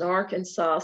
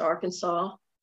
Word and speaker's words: Arkansas: Like it is actually a state law that Arkansas is pronounced Arkansas as opposed to Arkansas.